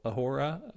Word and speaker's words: ahora: It's pronounced ahora. The H is silent.